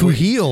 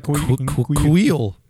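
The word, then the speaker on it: Kuiil: It's like Quheel almost, right? Like Quheel.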